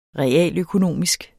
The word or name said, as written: realøkonomisk